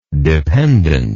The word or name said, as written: dependancy